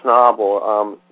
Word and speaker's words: Schnabel: And The Diving Bell and the Butterfly is a French-language film from Julien Schnabel. Please help me with the last name, because I always get his last name wrong. – You got it well enough.